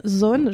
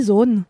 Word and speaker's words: Sohn: C'est quoi ton kiff euh, donc mon kiff est une, BD d'une autrice qui s'appelle euh, Lily Son, Zone, j'ai envie de le dire à l'allemande parce que ça s'écrit S-O-H-N, Lily Zone.